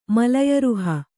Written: ಮಲಯರುಹ

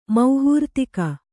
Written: ಮೌಹೂರ್ತಿಕ

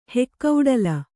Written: ಹೆಕ್ಕೌಡಲ

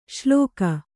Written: ಶ್ಲೋಕ

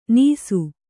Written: ನೀಸು

